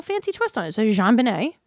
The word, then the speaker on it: JonBenét: Is it Jean? – I don't know. Some people pronounce it John, and then some people pronounce it Jean. – Well, I think it is. – I think it's JonBenét.